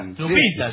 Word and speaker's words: Lupita: ¿Cómo se dice? ¿Lupita? – Lupita, sí.